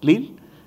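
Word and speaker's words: Lille: O Lil, más bien la pronunciación, Lil.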